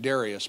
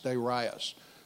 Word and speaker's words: Darius: And the Bible says in chapter 1, verse 1, in the second year of, I guess you pronounce this, I always pronounced it Darius, but I guess it's actually Darius. It's a long I, okay?